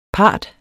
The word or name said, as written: part